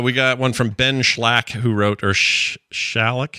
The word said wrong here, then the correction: Schalck: Shalik. Does that look right? I think I'm saying that right. – Shalk. – Shalk. – Shalk. – Shalk. – Shalk. – It's like S-C-H-A-L-C-K. Yeah, John's got it.